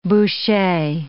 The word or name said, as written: bouchee